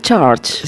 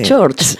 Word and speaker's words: Church: ¿Cómo has dicho? Charles. – Dije Church. Sí. Church. The Lord of the New Church. Church. – Dile otra vez. – Church. New Church.